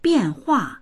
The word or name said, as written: bian4hua4